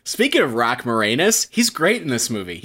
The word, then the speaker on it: Moranis: Now, that pronunciation of the last name. I've always said it Moranis, and yeah, I feel like too. Specifically because I think that's how he pronounces it, and I gotta assume the reason behind that being he's pronouncing his last name not as more anus.